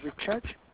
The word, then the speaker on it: Richard: Yep, my email address is Richard. – Can you just spell it for me? – Sure. R-I-C-H A-R-D.